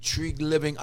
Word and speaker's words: Treliving: Tree Living.